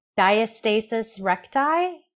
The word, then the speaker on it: diastasis recti: I can't pronounce this word, so you guys are going to have to help me. Diastasis recti? – That was perfect.